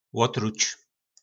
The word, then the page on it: lotruc: po nasimu